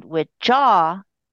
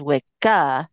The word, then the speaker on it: Wicca: Wicca is a female practitioner of Wicca, and Witchaw is the term of, all magical practitioners. So it's interesting that it's used today as a religion. Interestingly enough, though, they pronounce it as Wicca, not Witcha.